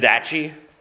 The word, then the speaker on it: Didache: The word Didache, I mean, I'm not pronouncing it. Didache? Right, it's like the Old Testament prophet Malachi.